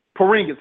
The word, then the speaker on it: Porzingis: Porzinski and Porzingis.